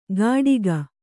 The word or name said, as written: ಕಟಿಗ